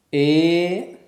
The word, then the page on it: e: sanskrit